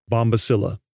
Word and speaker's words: Bombycilla: Bombycillidae comes from the genus name Bombycilla. The genus is spelled B-O-M-B, like the thing that goes boom, followed by Y-C-I-L-L-A, Bombycilla.